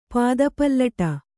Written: ಪಾದಪಲ್ಲಟ

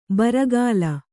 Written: ಬುರುಗಲು

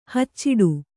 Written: ಹಚ್ಚಿಡು

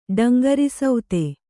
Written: ಡಂಗರಿಸೌತೆ

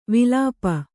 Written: ವಿಲಾಪ

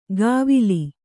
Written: ಗಾವಿಲಿ

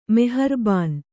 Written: ಮೆಹರ್ಬಾನ್

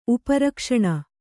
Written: ಉಪರಕ್ಷಣ

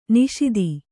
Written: ನಿಷೀಧಿ

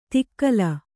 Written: ತಿಕ್ಕಲ

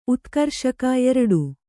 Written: ಉತ್ಕರ್ಷಕ

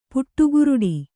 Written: ಪುಟ್ಟುಗುರುಡಿ

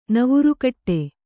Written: ನವುರುಕಟ್ಟೆ

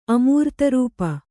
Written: ಅಮೂರ್ತರೂಪ

